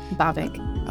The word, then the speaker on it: Bavinck: Yeah, he said he pulled a lot of his understanding of Herman Bavinck. Is that how you say it? – That's right. Yep, Bavinck.